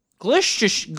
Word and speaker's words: Gloucestershire: I'm going to have to sit you two down and teach you how to pronounce English cities / towns because you butchered Gloucestershire. Gloucestershire.